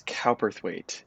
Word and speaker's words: Cowperthwaite: Did I pronounce your last name correctly? – Yes, it's Cowperthwaite. It's phonetic. It's exactly how it's spelled.